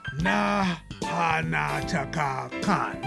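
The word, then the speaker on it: Nahnatchka Khan: I'm going to spell it out. N-A-H-N-A-T-C-H-K-A. Stop. K-H-A-N. Na-ha-na-cha-ka-khan. That's it. Boom. Got it. Nailed it. Yeah, that's how you say it.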